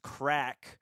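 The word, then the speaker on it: craic: It's pronounced crack. – It is. I knew what it meant, but I didn't know how to pronounce it. Thank you. I've only read it.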